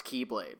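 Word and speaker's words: Keyblade (χ-blade): Spelled X-Blade, but it's pronounced Keyblade.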